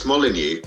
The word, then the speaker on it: Molyneux: Because it's it's Molyneux, but then I get in Ireland it's Munix, and then you get Molyneux, you get I've uh, been Moulinex, which was the make of kettle in the 80s it's up in all merengue was one. Someone pronounced my name Dave Merengue. like, it's awesome, but it's not my name.